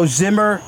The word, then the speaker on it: Zihmer: So Zihmer Law Firm.